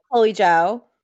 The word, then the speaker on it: Chloe Zhao: So the winner for the best director motion picture, Oh yeah, Chloe Zhao.